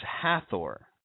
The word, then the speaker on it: Hathor: But her name is Hathor. Ha-Thor. You know, you can find our words like author also containing the god Thor. Let me think here. Oh, yes. Canaan. It's spelled C-A-N-A-A-N.